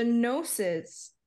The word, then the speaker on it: Gnosis: The gnosis, the gnosi, I can't talk. – The Nosois. – My pet project is to get people to pronounce the GN in Gnosis like the GN in Yoki.